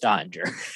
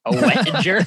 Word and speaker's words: Oettinger: The E is silent. It's just Oettinger. Oettinger.